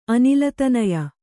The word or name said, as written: ಅನಿಲತನಯ